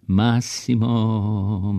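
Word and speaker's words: Massimo: Masimo, Masimo, sa onim zatvorenim O na kraju s francuskim akcentom.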